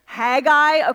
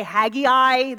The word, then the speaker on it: Haggai: Haggai, okay, that's how you pronounce it. There's also some people say Haggai, that's also correct.